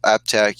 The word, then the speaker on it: APTAC: And that's spelled APTAC. So Google AppTech, you'll find a PTAC.